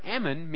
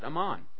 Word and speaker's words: Amman: But the word ammon means sand. And guess what? There's a city in Jordan called Ammon. They would pronounce it Amman.